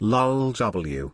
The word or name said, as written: lulw